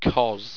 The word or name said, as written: chhos